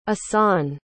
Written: assan

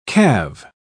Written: calve

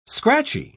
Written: scratchy

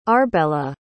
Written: arbela

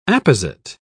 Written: apposite

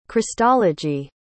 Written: christology

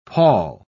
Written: pawl

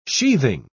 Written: sheathing

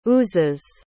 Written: oozes

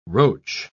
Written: roach

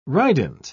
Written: rident

